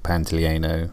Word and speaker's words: Pantoliano: John Pantolino. – Hi there. You might have just noticed that I said that guy's name wrong. His actual name is Joe Pantoliano.